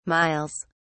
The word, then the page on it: miles: British English